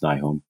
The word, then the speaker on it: Nyholm: So, and then also I have a Facebook author page, which is my full name, which is Todd Nils Nyholm, and Nils is spelled N-I-L-S, and then Nyholm is N-Y-H-O-L-M.